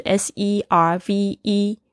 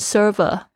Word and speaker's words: serve: 通常的供应会用这个字 s e r v e，不知道为什么有很多的学生会念成 server，不是 server，这边就是 serve 啊，那个 e 没有发音哦，serve。如果你讲成 server 的话，就变成它的名词，通常是讲服务员的意思。